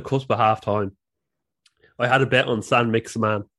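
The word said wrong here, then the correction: Saint-Maximin: Why did I say his name like that? San Maximin and Patrick Bamford to score in the game.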